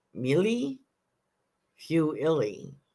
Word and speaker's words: mille-feuille: The name of the Italian pastry is Mille Fiuilli. I know I am not saying that correctly. M-I-L-L-E F-E-U-I-L-L-E.